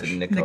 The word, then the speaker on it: Nikolaj: It's spelled Nikolaj, but I think it's Nikolai.